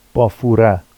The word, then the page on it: bɑfura: Vafsi